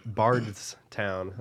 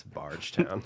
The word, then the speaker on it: Bardstown: Yeah, and they're like, "That's not. It's Bardstown." I was like, "Nah, it's Bargetown." – Thank Sorry. – You for correcting me. – Sorry. I'm from here. I know how it's said.